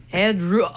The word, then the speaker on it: Headroom: Max. Endrum. Je, je me souviens plus si je le eh, prononce comme faux. – Max Headroom. Oh my God! – Ah oui, c'est comment? C'est Headroom. – Headroom.